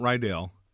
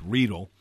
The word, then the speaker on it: Riedel: It is not Riedel. That's like the high school in Greece, right? – Right. It's Riedel.